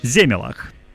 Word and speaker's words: Земелах: Земелах. Думаешь, это что-то другое? Тут я даже нашел, где ударение. На первую «е».